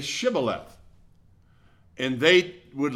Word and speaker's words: Shibboleth: The people of Ephraim pronounced Shibboleth. They left the H out.